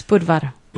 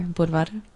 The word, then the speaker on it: Budvar: It's Budvar. – Budvar. Oh, yeah. So we roll the R, Budvar.